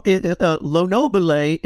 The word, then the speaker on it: Lenobile: And you are doing this collaboration with guitarist and producer Aldo Lenobile? Is that how we say his name? – Well, Lenobile, it would be the Italian pronunciation.